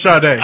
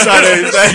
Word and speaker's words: Sade: yellow as Sade.